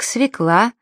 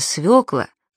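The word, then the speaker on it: свёкла: Если бы ее писали, то не было бы таких слов-уродцев, как «свекла», «планер» и «маневры». Ведь правильно «свекла», «планер» и «маневры».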